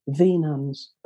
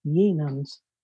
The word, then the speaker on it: Ye Nuns: So we changed it to Ye Nuns, which is actually the Y is actually an old English letter called the thorn, which is spelt it looks like a Y, but it's pronounced TH, so it's really still the Nuns, but it looks like Ye Nuns.